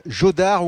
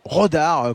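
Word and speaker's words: Jodar: Et puis l'attraction, la nouvelle star du tennis, le jeune Raphaël Jodard ou Rodard, comme vous voulez, si vous le prononcez à l'espagnol ou à la française,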